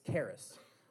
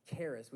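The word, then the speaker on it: charis (χάρις): These, these graces or these gifts, the, the, the, the, the original word there is, um, uh, well, you can't really pronounce it in English, but it's, it's, it's, it's charis. Uh, that's the, the closest pronunciation. It's charis,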